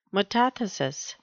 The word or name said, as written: metathesis